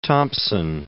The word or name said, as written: Thompson